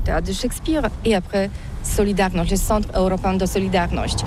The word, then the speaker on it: Solidarność: Alors solidarité, en français on dit solidarnosc, mais ici à Gdansk on ne prononce pas solidarnosc.